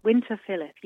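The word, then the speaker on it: Winterfylleth: Is it pronounced Winterfilith? – Winterfilith, yes, I think so.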